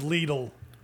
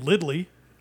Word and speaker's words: Lidl: The I in Lidl is pronounced as a long E, which means it is pronounced with an EE sound and not with a short I.